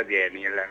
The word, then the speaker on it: Daniele: Allora i genitori hanno deciso, scritto, ci tiene a dirlo, scritto Daniele, si pronuncia Daniel, no? – Daniel.